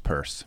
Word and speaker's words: Peirce: I, I, my name's actually pronounced purse.